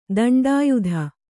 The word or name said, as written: ದಂಡಾಯುಧ